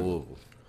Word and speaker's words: Louisville: That's how they say it for real. Like they say it like all together. – Louisville.